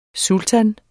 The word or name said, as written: sultan